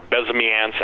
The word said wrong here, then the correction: Bezmenov: I can't pronounce that guy's name. You know, the B guy that's on the video with the glasses. – Bezmenov, yeah. – Bezmenov. There you go.